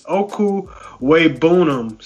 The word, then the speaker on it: Okwuegbunam: Yeah, they say Albert O, but I got the pronunciation. It's Okwuegbunam. So Albert O is what you're going to call him in the league.